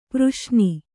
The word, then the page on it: ಪ್ರಶ್ನೆ: praśne